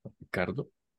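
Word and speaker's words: Ricciardo: Pues, ah, como a grandes rasgos, creo que a ah, muchos nos sorprendió el pacing que tuvo el Alfa Tauri y Richardo, Ricardo. – ¿Cómo se dice ese nombre? – creo que es Ricardo.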